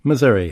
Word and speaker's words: Missouri: how is Missouri really pronounced? – Missouri is how I pronounce it, because I'm from Minnesota.